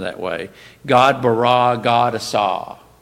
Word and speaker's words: asah: And that word is spelled A-S-A-H, asah.